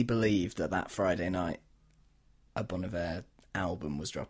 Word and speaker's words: Bon Iver: Which is someone who, everyone who regularly listens to this podcast will know, we love Bon Iver, Bon Iver. – I was so pleased that you said it right the first time and then you didn't.